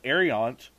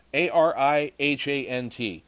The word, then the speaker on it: Arihant: It was reported that the nuclear powered INS, Ar- uh, sorry if I kill this, but Arihant, A R I H A N T, that's how it's spelled, Arihant, became.